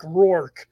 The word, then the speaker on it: O'Rourke: So phonetically, it's Rourke, just like you're showing it on the screen.